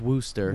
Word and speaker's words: Worcester: It's spelled like Worcester. Worcester. – Oh, Worcester. – The sauce. – But they, yeah, it's real close to the sauce name. But it's pronounced Worcester.